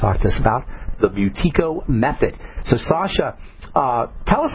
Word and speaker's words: Buteyko: We're talking to Sasha Yakuleva, an expert in the Buteyko breathing method, and that's spelled B-U-T-E-Y-K-O, Buteyko breathing method, and Russian Healing Arts.